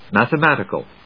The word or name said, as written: Mathematical